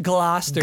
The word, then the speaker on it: Gloucester: Oh, anyway, like anyone from Gloucester, and there's like, you say it Gloucester.